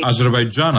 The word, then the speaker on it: Αζερβαϊτζάν: Είχε πάει και η Ντόρα για δουλειές στο Αζερβαϊτζάν πριν κλείσει το θέμα του αέριου. – Αζερβαϊτζάν, αγάπη μου, Αζερβαϊτζάν. Μίλα το καλά, αφού το λες.